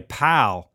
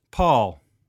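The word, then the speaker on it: Pål: I was just going to call you Pal because it's P-A-L, but it's actually pronounced Paul.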